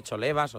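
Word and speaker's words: Cholevas: Entonces, claro, había que ver si era Jolebas o Cholebas o tal. Entonces yo le dije que en Forbo decían Jolebas, pero Sisto Miguel Serrano, que es una institución, decía que no, que se pronunciaba de la otra manera.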